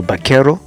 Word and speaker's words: vaquero: et le vaquero en bon espagnol, le V, on le prononce B, donc ça se dit plutôt vaquero, ce qui donne donc en anglais buckaroo, en tout cas dans l'anglais du sud de la Californie.